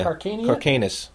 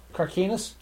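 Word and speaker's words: Karkanis: I can't pronounce her name. Athena Carcania. – Carcanus. – Carcanus.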